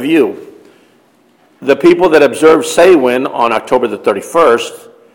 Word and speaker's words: Samhain: It's spelled Samhain, but it's pronounced Samhain, or summer's end.